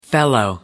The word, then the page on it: fellow: American English